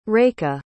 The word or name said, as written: rekha